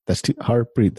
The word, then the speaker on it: Harpreet: Like, my name, Harpreet. Harpreet, that's 2 syllables. Like, it's not hard at all, Harpreet. – Like, I mean, Jonathan, Nathaniel. All these names have more syllables than my name.